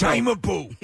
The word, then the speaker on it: Cimabue: It's Cimabue.